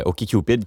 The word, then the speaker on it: OkCupid: OkCupid, c'est comme, bon, je vais bien le prononcer, là, OK, Cupid comme d'accord, Cupid comme Cupidon.